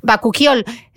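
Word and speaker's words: bakuchiol: Ahí tenemos una montada, que es bacuchiol Bacuquiol. Ah, sí, porque sí, bacuchiol Bacu, para que nos entiendan, he escrito Bacuchiol.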